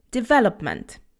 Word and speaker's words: development: Hello, hello, this is Anglojęzycznie, and today we have a difficult word, and that word is development. Development. Remember that the stress is on the ve syllable, development. So there is no O sound. It's not development, okay? It's development. Development.